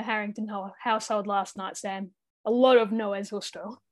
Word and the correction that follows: No es justo: How do you say it? – No es justo.